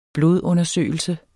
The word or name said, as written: blodundersøgelse